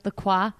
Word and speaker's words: LaCroix: Is that how you say it? LaCroix? LaCroix? – LaCroix.